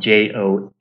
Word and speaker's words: Johnathan: And I do have to note, Bruce, my parents cursed me with an H in my name, which means I miss about 50% of the emails coming my way. So my first name is spelled JohnAthan.